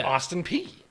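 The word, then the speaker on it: Peay: No, it's pronounced just like the letter P. – Yeah. – It's Austin P.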